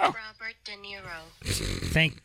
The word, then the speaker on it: Robert De Niro: Robert De Niro. – There you go. – Robert De Niro. – That sounds great. – Pull up a sports. – No. – Robert De Niro.